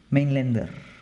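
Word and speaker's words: Mainländer: Mainlander, este, em, para pronunciarlo ahí un poquito más, más en la parte alemana.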